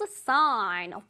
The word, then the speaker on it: sign: 이것도 마찬가지로 sign 할때 G 소리를 내면 안 됩니다. Signal 아니에요. Sign.